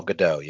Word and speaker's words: Gadot: Is it Godot? Is it Gal Gadot? It's yeah. Gal Gadot, yes.